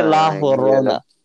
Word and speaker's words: La Llorona: It's like fucking 30 seconds ago, but La Llorona. Or they say La Llorona. – Just like that. – Yeah, exactly like that. That's how they say it.